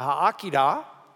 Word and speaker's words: Akedah: we would talk about the Akedah, that's the way you'd pronounce it, the Akedah, which is the binding.